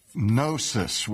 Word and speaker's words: Gnosis: Now, when we spell Gnosticism, we spell it with a G, G-N-O-S-T-I-C-I-S-M. And Gnosticism comes from the Greek word Gnosis, which is also spelled with a silent G, G-N-O-S-I-S, which is simply the Greek word for knowledge.